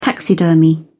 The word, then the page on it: taxidermy: British English